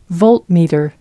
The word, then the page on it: voltmeter: American English